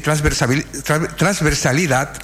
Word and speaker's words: transversalidad: Y en segundo lugar, queremos un reglamento de subvenciones por la necesidad de aplicar criterios de, transversabilidad lo he dicho bien, sí, transversalidad perdón, en la gestión municipal.